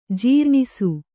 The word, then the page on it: ಜೀರ್ಣಿಸು: jīrṇisu